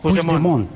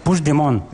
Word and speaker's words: Puigdemont: No, Puigdemont. – Puigdemont. – Vale. – Puigdemont. – Eso es. – Sí, lo dices como francés, así. Tiene Puigdemont, como parece como una isla.